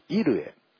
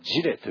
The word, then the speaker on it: Jireh: ジェホバもアドナイも、ね、そしてアドナイイルエ、このイルエ、ね、まあ、英語の聖書では、ね、 J-I-R-E-H、ねね、あの J の字をイエというふうに発音する国もまたありますから、ですからアドナイイルエというふうに発音する人もおりますし、ジェホバジレというふうに発音する人もありますけど、いずれも、ね、ただ単なる発音の違いで、全て一つの意味です。